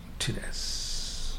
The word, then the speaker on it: Tiddas: Tidass. – T-I-D-D-A-S.